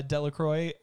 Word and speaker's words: Delacroix: Yeah, Delacroix. He's a very ridiculous Cajun name. Mr. Delacroix is what they called him. – Delacroix.